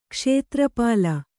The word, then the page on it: ಕ್ಷೇತ್ರಪಾಲ: Kannada